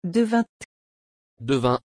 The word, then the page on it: devin: French